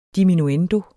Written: diminuendo

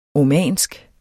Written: omansk

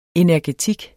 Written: energetik

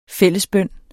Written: fællesbøn

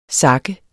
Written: sakke

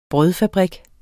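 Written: brødfabrik

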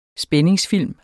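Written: spændingsfilm

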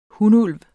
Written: hunulv

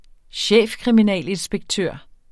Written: chefkriminalinspektør

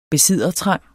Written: besiddertrang

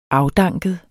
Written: afdanket

